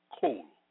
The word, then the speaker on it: kol: The Hebrew word for voice is kol, pronounced kol, meaning voice, sound, noise, thunder, thundering.